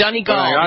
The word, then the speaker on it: Donegal: And she, the first thing you did was make sure I pronounced Donegal correctly. – It's Donegal. You're still saying it wrong.